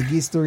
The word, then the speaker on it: Magister: Actually, it's pronounced Magister if you want to be... – Magister. – Really, Magister is the actual Latin pronunciation.